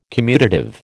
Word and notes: commutative: American English

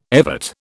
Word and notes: evert: British English

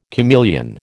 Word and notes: chameleon: American English

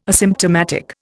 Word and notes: asymptomatic: Indian

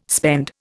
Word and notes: spend: Indian

